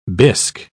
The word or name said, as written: bisque